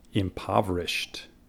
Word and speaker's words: impoverished: Primero, Esa palabra, cerca de la terminación, se pronuncia impoverished. Impoverished.